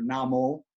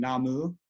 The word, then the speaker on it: Namu: So in most Buddhist traditions, in Chinese you hear Namo, the Japanese pronunciation being Namu.